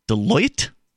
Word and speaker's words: Deloitte: according to research released Thursday by Deloitte, D-E-L-O-I-T-T-E.